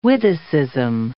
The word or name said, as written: witticism